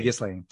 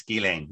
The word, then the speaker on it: Ghislaine: That's how you say Ghislaine. – That's how you say it in France. Ghislaine.